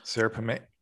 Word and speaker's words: Serapeum: So the story begins with the destruction of the, and how would you say it, the Serapeum? – I think so. Serapeum? – Yes. Serapeum.